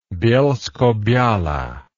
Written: bielskobiala

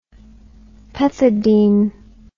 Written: pethidine

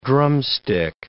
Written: drumstick

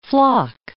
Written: flock

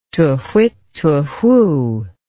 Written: tu-whit tu-whoo

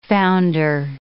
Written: founder

